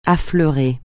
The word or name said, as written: affleurer